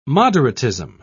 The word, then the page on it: moderatism: British English